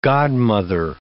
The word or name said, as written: godmother